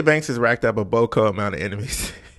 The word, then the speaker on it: beaucoup: Now, a lot of y'all niggas, including myself, pronounce it buku like savage shit. But it's actually pronounced boku. Boku is an adjective, and it's spelled B-E-A-U-C-O-U-P. Boku.